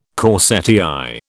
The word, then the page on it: corsetière: British English